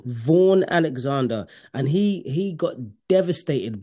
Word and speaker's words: Vaughn: No, it's I think his name, you pronounce it like Van. It's like a V-A-U-G-H-N, Van. It's not Van Gogh, but it's like Van.